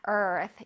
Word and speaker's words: Earth: 자, Earth. 이거 얼뜨라고 읽는 친구들이 있어요. 얼뜨 아니고 Earth. Earth. 선생님, 혓바닥 어디 있어? 뜨뜨뜨. Earth. Okay.